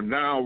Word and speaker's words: NAO: What I do, the robots that I use is called the Now Robot, and it's spelled NAO.